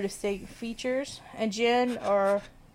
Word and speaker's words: jinn: A form of shadow people in Islamic folklore is the jinn, also spelled as D-J-I-N-N. I think it's still pronounced jinn. – Yeah, it's still jinn. – Yeah, or genie.